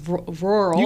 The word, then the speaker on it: rural: If you go to, you know, these woodsy areas and rural, I can never pronounce rural. – You got it, rural.